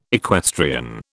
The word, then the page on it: equestrian: British English